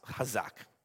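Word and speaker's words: hazak (חזק): It's in Hebrew behind me in the first line, and it's pronounced hazak.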